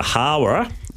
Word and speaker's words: Hawera: Okay, we go to Hawera, and we've got race six to look out there as well. – I'm glad you said that right, because I didn't know how to pronounce that.